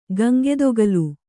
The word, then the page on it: ಗಂಗೆದೊಗಲ್: kannada